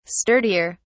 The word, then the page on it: sturdier: British English